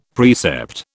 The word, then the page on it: precept: British English